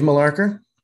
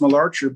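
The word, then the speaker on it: Malarcher: Dave Mularker. – Oh, okay. – I may not be saying that right. – Yeah, I think it's Mularcher, but he's a Chicago guy.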